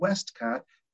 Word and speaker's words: Westcott: But you can reach me at W Westcott, and I'll spell that because it's has 3 T's. It's W for my first initial, my first name is Wayne. So W, and then Westcott, WESTCOTT.